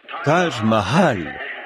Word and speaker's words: Taj Mahal: L'hôtel Taj Mahal? Taj Mahal.